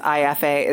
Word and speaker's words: Aeffe: Yes, IFA is spelled AEFFE.